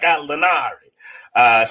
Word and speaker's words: Gallinari: It's like you got to almost say it like Gallinari.